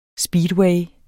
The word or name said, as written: speedway